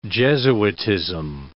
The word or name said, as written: jesuitism